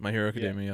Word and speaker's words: My Hero Academia: My Hero Academia, yes.